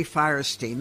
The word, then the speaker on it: Fierstein: And not only has he written his memoir, I Was Better Last Night, Harvey Fierstein, that's how his mother taught him to pronounce it. I could get them mixed up. His father called it Stein, I think.